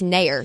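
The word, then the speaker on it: Naeher: It's Nair. Like it's Nair. – As in like it's like Nair, wax. Like John Mayer, shorts. But with an N. It's just Nair.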